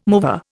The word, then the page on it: mover: Indian